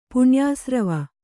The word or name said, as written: ಪುಣ್ಯಾಸ್ರವ